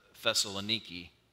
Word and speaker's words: Thessaloniki: Today it would be pronounced, we call it Thessalonica, but the Greeks pronounce it Thessaloniki.